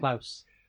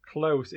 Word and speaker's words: Close: I don't know if I pronounced that well enough, but Klaus. – Close. It's not about Bono's German pen friend.